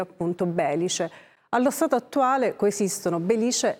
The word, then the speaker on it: Belice: Proprio per questo motivo a volte si utilizza l'accento sulla I, Belice, proprio per sottolinearlo.